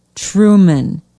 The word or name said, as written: truman